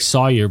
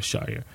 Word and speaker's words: Shawyer: It looks like Sawyer, but it's spelled Shawyer. So I'm just going to go with Shawyer.